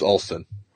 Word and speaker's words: Alston: getting on me because I keep mispronouncing it because I don't have my glasses on. And it looks like Allist, uh, Alliston, but it's Alston.